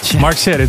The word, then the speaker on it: Mark Sherry: This week, Mark Sherry sent me an email that I said his name with a wrong accent. Mark Sherry. Mark Sherry, that's how you say it, right?